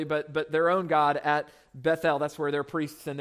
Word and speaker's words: Beth-El: He prophesied to the northern kingdom of Israel, and the northern kingdom had a temple at a city called Beth-El, which again, that's, we call it Bethel, right? But when you pronounce it that way, Beth-El, Beth means house, El means God, so Beth-El is the house of God, right?